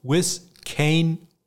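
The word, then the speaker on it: Vizcaino: Ist jetzt die Frage, wie wird Tristan Wiskano ausgesprochen? Wiskano, Wiskaino, Wiskino. Ich habe Antwort bekommen von seinem Kicking-Coach Nick Novak, der sagt Tristan Wiskano.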